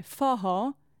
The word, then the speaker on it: forró: ma in brasiliano sarebbe forró con una doppia R che sembra una H. – Che sembra una doppia H, fondamentalmente.